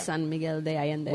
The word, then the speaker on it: San Miguel de Allende: is shooting Top Chef in San Miguel Allende. Am I saying that right? – Yes, yeah. San Miguel de Allende.